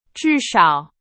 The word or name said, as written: 至少